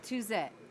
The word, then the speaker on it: Touzet: Touzet.